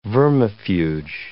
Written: vermifuge

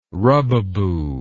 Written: rubaboo

